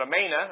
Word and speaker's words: Amana: Now we call it Amana here in modern days, but Amana, that's a long A there.